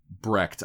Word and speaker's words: Brecht: So I'm going to pronounce his name correctly. – Which is Brecht? – Brecht, yeah. – Brecht. Well, Americans say Brecht. – Brecht. – But they're wrong.